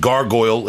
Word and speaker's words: Gargoyl: You just released new music with Gargoyl.